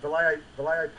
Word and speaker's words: Vallejo: And I'm seeing that, are you still painting with Vallejo? Is that how to pronounce it? – Yeah, Vallejo paints, yeah.